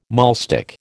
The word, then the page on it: maulstick: American English